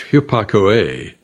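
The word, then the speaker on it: hupakoe (ὑπακοή): In fact, the Greek word for obedience, pronounced hupakoe, is made up of two parts.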